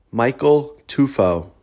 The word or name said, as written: Tufo